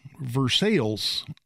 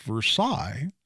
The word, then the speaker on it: Versailles: and the county seat is Versailles, Indiana. And if you're listening to me say Versailles, and you're familiar with geography much, you may be rolling your eyes, going, "Well, no, you idiot! It's pronounced Versailles."